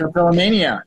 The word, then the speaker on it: trichotillomania: trichotillomania.